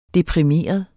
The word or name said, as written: deprimeret